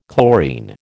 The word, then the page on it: chlorine: American English